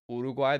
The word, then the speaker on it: Uruguay: Also, no one can say their name right. It's Uruguay. There's no "ur" in that.